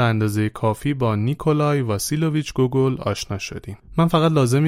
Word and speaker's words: گوگول: تلفظ اسمش به فارسی، روسی و انگلیسی کاملا متفاوته. امیدوارم ایراد نگیرید از اینکه من چرا گوگل تلفظش کردم.